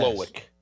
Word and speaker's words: Slowik: Um, how do you pronounce that name? Swolick. – Oh, yes. Slowik. – Slowik.